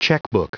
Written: checkbook

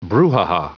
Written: brouhaha

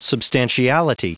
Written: substantiality

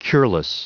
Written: cureless

